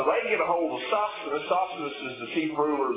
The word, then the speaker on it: Sosthenes: By the way, we find out in 1 Corinthians chapter 1 that Sosthenes, I didn't say his name, Sosthenes, there it is, that the Lord saved him as well.